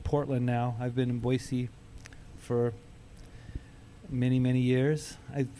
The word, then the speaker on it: Boise: There's no Z in there. – Boise.